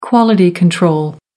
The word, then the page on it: quality control: American English